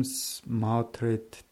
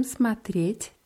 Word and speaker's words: смотреть: Мы будем смотреть телевизор. – Мы будем смотреть телевизор. Bei смотреть ist die Betonung auf dem je.